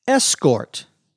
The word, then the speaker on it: escort: There is a noun that comes from this verb, escort. The accent is on the first syllable.